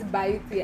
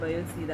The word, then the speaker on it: Byoote: So what is Biuti? And Biuti is spelled B-Y-O-O-T-E.